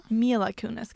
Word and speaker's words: Mila Kunis: Och det är Miley Kunis. – Yes! – I knew that bitch. – Okej, då tar vi nästa. – Mila Kunis kanske uttalas. – Milan. – Mila. Nej, bara Mila. Kunis.